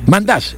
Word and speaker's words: Mandash: Para el equipo árabe marcó el sultán Masdenash. Mandash. Lo que pasa es que se escribe un poco raro, pero es Mandash lo que tiene razón.